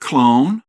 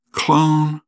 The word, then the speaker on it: clone: Until the 20th century, the word was spelled C-L-O-N, and then, in the 20th century, added an E to the end of the word to indicate the O was a long O and pronounced clone and not clon.